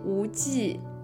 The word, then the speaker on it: 无忌: deutsches Wort yes 呢，嗯，就是说大家可以说啊，张无忌，无忌 is v jeep 啊，那德国人就知道他应该怎么发音了。